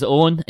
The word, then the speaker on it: Eoghan: Hi, my name is Owen, and you can find me on Twitter at howwerolloan. Owen is spelled E-O-G-H-A-N, because Irish spellings.